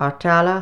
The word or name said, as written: ARDTALLA